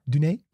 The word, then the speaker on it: Dune: Dune.